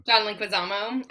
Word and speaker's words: Leguizamo: or how do you say his name? – John Leguizamo.